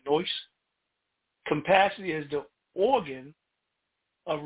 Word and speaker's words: nous: When you look in the Strong's Concordance and you look in the Greek version, the Greek word for capacity is gnosi. I think I'm saying that right. It's nous, N-O-U-S.